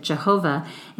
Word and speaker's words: Jehovah: Yahweh, as we say in English. Many Christians pronounce it Jehovah, and it is often translated into our English Bibles as Lord.